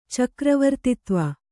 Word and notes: ಚಕ್ರವರ್ತಿತ್ವ: kannada

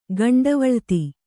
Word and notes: ಗಂಡವಳ್ತಿ: Kannada

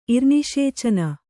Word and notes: ಇರ್ನಿಷೇಚನ: irniṣēcana

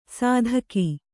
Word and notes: ಸದುಕ: Kannada

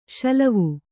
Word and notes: ಷಿಲ್ವೆ: ṣilve

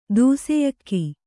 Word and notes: ದೂಸೆಯಕ್ಕಿ: dūseyakki

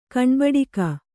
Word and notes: ಕಣ್ಬಡಿಕ: Kannada

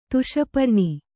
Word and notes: ತುಷಪರ್ಣಿ: tuṣa parṇi

